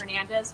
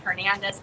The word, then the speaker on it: Hernandez: So my name is Amberin Hernandez, which is spelled A-M as a monkey, as a bear, R-E-E-N as a Nancy, Hernandez, H-E-R-N-A-N-D-E-Z.